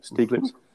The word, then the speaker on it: Stieglitz: Is that how you pronounce his surname? Stieglitz? – Yes.